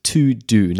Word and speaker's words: Teux Deux: And he recommended this app, which is called To-Do. Now, it's not spelt the way you would normally spell To-Do. It's spelt T-E-U-X-D-E-U-X.